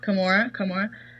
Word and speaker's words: Camorra: Camorra.